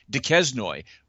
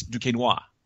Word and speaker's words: Duquesnoy: Now, when you look at Duquesnoy's last name, we are taught that N-O-I is pronounced Noir. Okay. If you see N-O-Y, it's Noy. So we look at that and we say Duquesnoy, when in reality, it's Duquesnoy.